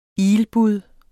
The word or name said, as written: ilbud